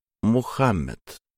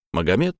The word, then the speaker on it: Мухаммед: Великого пророка звали Мухаммед. Магомед пришло к нам из французского языка. Пора переучиваться. Мухаммед, Мухаммед, Мухаммед с ударением на втором слоге.